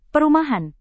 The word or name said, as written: perumahan